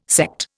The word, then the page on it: Sekt: Indian